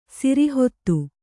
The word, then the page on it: ಸಿರಿಹೊತ್ತು: Kannada